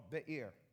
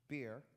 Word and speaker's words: Be'er: Well, they get to this place called Be'er. It's not pronounced beer, all right? In the Hebrew, it's Be'er, and it means well.